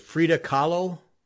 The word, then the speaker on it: Frida Kahlo: Is it Frida Kahlo? – Yeah.